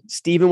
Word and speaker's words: Stephen: In the meantime, my name is spelled Stephen with a PH, Scoggins, 2 G's.